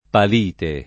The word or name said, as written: palite